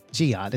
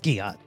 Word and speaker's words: Ghiat: I'm sorry I said your name with the hard G. Uh, Ghiat, it's Ghiat.